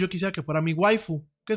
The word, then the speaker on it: waifu: Entonces, en vez de decir wife, ellos dicen waifu, waifu, así como tal, y lo escriben waifu.